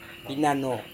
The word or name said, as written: pinano